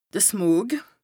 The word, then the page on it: de Smog: Bas Rhin